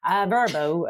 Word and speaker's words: Vrbo: Vrbo, as we spoke, you're supposed to pronounce it like a word. It's very pleasant. It's like Brno, isn't it? – This sounds like, I'm in the West Country.